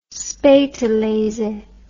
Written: spatlese

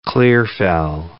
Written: clear-fell

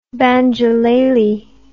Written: banjolele

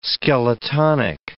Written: skeletonic

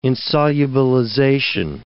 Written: insolubilization

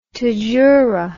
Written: tajura

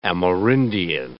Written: Amerindian

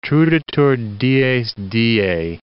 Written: truditur dies die

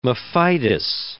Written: mephitis

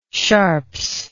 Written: sharps

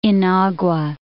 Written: Inagua

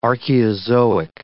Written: Archeozoic

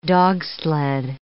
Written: dogsled

